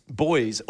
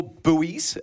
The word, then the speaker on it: buoys: Where they both discovered numerous boys, or buoys, as they're pronounced for our Northern American listeners, in the water.